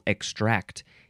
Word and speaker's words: extract: Emphasize the second syllable, extract. E-X-T-R-A-C-T. It does say in sense 5, you would emphasize the first syllable, extract.